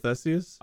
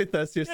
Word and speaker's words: Theseus: The ship of Theseus? – I'm sorry, did you just mispronounce a word that was said to you pronounced correctly previously? – Was it did I say Theseus? – Yeah, you said Theseus.